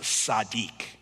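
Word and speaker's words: sadiq (צדיק): There's a real rich history behind this idea that the Hebrew word for a righteous man is pronounced sadiq. Sadiq.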